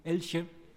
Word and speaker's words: Elche: When I say there are no synagogues, you may relate to the question of the building at Elche. I'm not sure how to pronounce it, all that town. Elche, thank you.